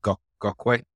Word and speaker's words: Gokwe: I don't want to pronounce it incorrectly. Was it Gokwe? – Yeah, you got it right.